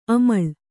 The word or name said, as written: ಅಮಲು